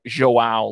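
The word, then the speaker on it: João: J in Spanish is like an H, whereas in Brazil, it's like Joao, like it's saying John, it's Joao instead of, you know, with the age.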